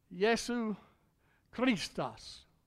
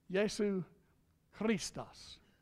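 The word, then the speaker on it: Iēsous Christos: And that's why God gave His best gift, Jesu Christos. That's how you say it in Greek there, brother. Jesu Christos.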